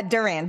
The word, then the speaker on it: Duran: Uh, Duran, just like Duran Duran.